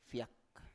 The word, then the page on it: fiak: Biak